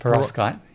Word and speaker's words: perovskite: And this technology, I hope I've got the pronunciation correct, is it perovskite? – Perovskite, yeah.